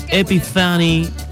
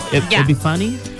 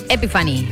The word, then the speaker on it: Epiphany: Quisiera un temita Epiphany de BTS, porfis. ¿Lo dije bien o no? – Sí. Horrible. – Epiphany. – Epiphany.